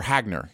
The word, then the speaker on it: Hagner: Um, and then John Early, uh, John Reynolds, and Meredith Hanger uh, or Hagner, excuse me, uh, are the four main characters.